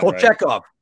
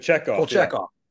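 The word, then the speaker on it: Kochetkov: Kachikov. I think I'm pronouncing that Kolchekov. – Right. Kolchekov.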